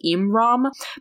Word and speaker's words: immram: So, about this genre, specifically for Irish stories, this tale is called an immram, which is spelled I-M-M-R-A-M. So it looks like immram, but my professor, my Irish professor, always said immram.